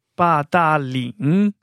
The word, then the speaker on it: Badaling: Hoe, spreek je deze namen werkelijk uit? – Badaling.